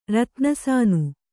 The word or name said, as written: ರತ್ನಸಾನು